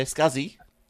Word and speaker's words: SCSI: I always called it Scuzzy or whatever it was. – Yeah, Scuzzy.